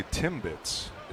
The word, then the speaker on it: Timbits: I think we got some rally Timbits is what we got.